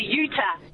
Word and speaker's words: Utah: We've got, is it Jitar? Is that how I pronounce it? – I think you're talking to Utah. – Utah. – Yeah, like Utah in America.